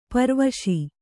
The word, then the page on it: ಪೆರ್ವಸ: kannada